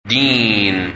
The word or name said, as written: Deen